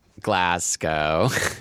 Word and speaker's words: Glasgow: Yes, we're recording this live from Glasgow. – Oh, you had to what's a Scotland place? – But you pronounced it correctly, which is often people do not. – Oh, not like Glasgow. – People always are saying Glasgow. Glasgow.